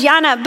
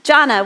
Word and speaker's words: Jana: It was just the Spanish pronunciation of my name instead of the English, which is Jana, which many people get that wrong. They were actually closer. Most people want to call me Jonah.